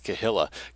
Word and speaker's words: Kehillah: K-E-H-I-L-L-A, Grips, New York. Rule of the Jewish Kehillah, Grips, New York. And I looked up the pronunciation, and that's what they're saying it is, Kehillah.